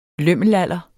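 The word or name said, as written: LØMMELALDER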